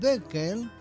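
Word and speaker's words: Boelcke: Y luego insistió hasta que conoció a un señor llamado Oswald Belke, hay que pronunciarlo muy alemán,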